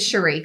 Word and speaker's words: Sheree: Why am I saying Sherry? You know why? – Why? – Because in my other office, it was a Sherry spelled like that. It's Sheree.